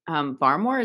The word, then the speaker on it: Barmore: Not a lot of talk about the interior defensive linemen in this year's class, but I do think that Christian Barmore, is that how you say his name? – Yep.